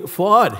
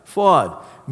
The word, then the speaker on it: Fouad: Call me Fouad. Hey, that's an easy name. Let me tell you how you say it. Quad with an F. Fouad means loving heart.